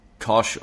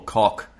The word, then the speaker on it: Koch: If you want to buy a brand new, let's say, do you call it Koch amp? – How do you spell that, sorry? – K-O-C-H. – Oh, yeah. Well, it could be pronounced Kosh or Cock. It depends where you're from.